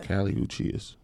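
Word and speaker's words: Kali Uchis: 4 is Kaylee Uchis. Is that how you say it? – Kaylee Uchis.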